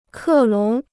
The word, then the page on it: 克隆: kè lóng